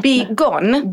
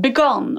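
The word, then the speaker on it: Begoun: Uttalade jag rätt? – Begann. – Begann var det. – Mm. Jag fick lära mig det av henne. Hon är väldigt picky med att man uttalar hennes namn. – Begann. Ja. – Som Begann.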